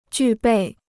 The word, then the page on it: 具备: jù bèi